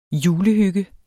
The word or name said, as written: julehygge